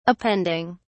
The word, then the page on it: appending: British English